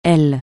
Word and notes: l: francais

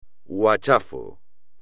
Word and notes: huachafo: espagnol

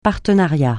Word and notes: partenariat: francais

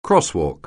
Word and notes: crosswalk: anglais

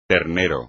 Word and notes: ternero: espagnol

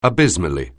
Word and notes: abysmally: anglais

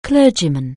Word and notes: clergyman: anglais

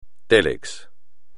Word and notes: télex: espagnol

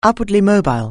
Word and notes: upwardly mobile: anglais

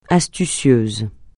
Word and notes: astucieux: francais

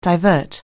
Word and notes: divert: anglais

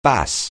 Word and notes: bass: anglais

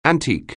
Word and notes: antique: anglais

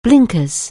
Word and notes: blinkers: anglais

